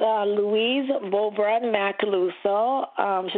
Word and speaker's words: Louise Beaubrun-Macaluso: Yes, it's Louise Beaubrun Macaluso.